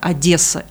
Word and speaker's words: Одесса: Если вы хотите сделать приятное одесситу, то произносите мягко Одесса и только Одесса.